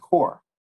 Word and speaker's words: corps: Well, I think he meant core and not corpse. At least I hope. Corpse. C-O-R-P-S. – It's core. It's pronounced core, Joseph.